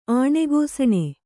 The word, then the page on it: ಆಣೆಗೋಸಣೆ: Kannada